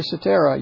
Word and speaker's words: Cetera: Not with Peter Cetera, you pronounce a C like an S.